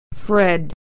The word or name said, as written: Fred